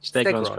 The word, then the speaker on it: Stegron: Like, Stegron, correct, yeah.